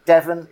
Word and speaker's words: Devon: Devon, it rhymes with heaven.